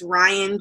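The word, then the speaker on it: Rhyan: And So I am on Instagram, and it's Ryan J.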